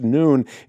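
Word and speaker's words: noon (ن): It's pronounced noon, and it stands for Nazarenes, a pejorative Arabic word for Christians.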